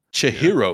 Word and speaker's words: Chihiro: So I'm not sure if he was excited, but to get into it, Spirited Away is the story of 10-year-old Chira. How do I say her name? – Chihiro, excuse me, guys.